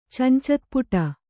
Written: ಚಂಚತ್ಪುಟ

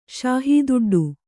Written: ಶಾಹೂದುಡ್ಡು

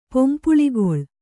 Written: ಪೊಂಪುಳಿಗೊಳ್

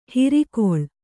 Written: ಹಿರಿಕೊಳ್

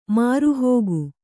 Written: ಮಾರುಹೋಗು

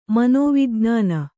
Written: ಮನೋವಿಜ್ಞಾನಿ

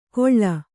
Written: ಗೊಳ್ಳ

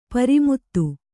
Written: ಪ್ರಮದೆ

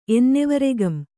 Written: ಎನ್ನೆವರೆಗಂ

